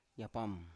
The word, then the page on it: yapam: Biak